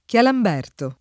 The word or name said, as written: Chialamberto